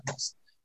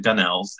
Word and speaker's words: Gunnells: There's the Gunnells with two L's and then there's the Gunnells with one L, and we're a different tribe, but we're both Gunnells. But most people pronounce it Gunnells, and I've just learned to accept it.